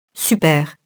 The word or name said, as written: super-